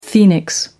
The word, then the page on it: phoenix: American English